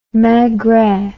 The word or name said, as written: Maigret